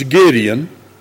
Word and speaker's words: Gideon: For the time would fail me to tell of, that's spelled G-E-D, but it's Gideon, and of Barak, and of Samson, and of Jephthah, and of David also, and Samuel, and of the prophets.